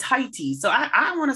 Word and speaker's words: Haiti: That's how I think you're supposed to actually pronounce Haiti. Haiti. – Haiti? – Haiti. – I think that's like if you're French, if you're a French speaker.